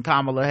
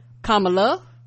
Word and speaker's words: Kamala: Tanya W says, I have also struggled with pronouncing Kamala Harris's name, so I came up with a mnemonic. Kamala is calm.